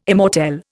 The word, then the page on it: immortelle: Indian